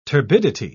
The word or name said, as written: turbidity